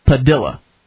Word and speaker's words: Padilla: According to his lawyer, his family's preferred method of pronunciation for their last name is Padilla. Like armadilla.